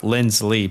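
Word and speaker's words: Linsley: Lindsley, by the way. I know we keep saying Lindsey. It's just so hard to say his name properly.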